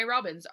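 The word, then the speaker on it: Robins: And I am Ray Robbins, R-A-E-R-O-B-I-N-S.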